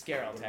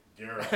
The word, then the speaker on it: Geralt: It's Geralt, actually. – Geralt. – It's hard G.